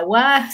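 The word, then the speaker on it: wa (は): La wa se escribe ja y se dice wa, la o se escribe wo y se dice o.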